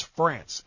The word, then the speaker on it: Frantz: It's France, F-R-A-N-T-Z.